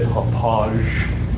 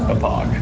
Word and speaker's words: Hauppauge: Looks like it should be said Hopage. – It's the Hopage River in New York. – Named after the city. – Can you spell it for me, please? – Yes, because it's on the remote. – How do you spell? – H-A-U-P-P-A-U-G-E. – Oh, is that how you say it? Hapog? – Hapog.